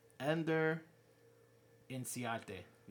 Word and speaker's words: Inciarte: Ender Inciarte. Yeah, that's how you say it.